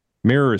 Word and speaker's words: mirror: Mirror. Mirror is two syllables. Mirror. – Mirror. – It's two syllables.